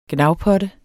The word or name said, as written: gnavpotte